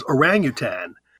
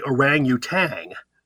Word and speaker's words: orangutan: And again, it's by good English rules is orangutan. Most people say orangutang. They add the G at the end.